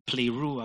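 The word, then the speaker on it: pleru (πληρόω): It's from a root word, pleru, which is from the word pleru, P-L-E, or pleru, actually, that's how it's pronounced, P-L-E-R-O-O. And pleru means to fill up to the full, to make full, hence to fulfill.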